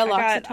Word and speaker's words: L'Occitane: Oh, yeah, L'Occitane.